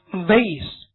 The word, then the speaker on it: vase: 再举例，请看 delicate， N 多意思，vase，花瓶，有人念作 vase 也可以啊。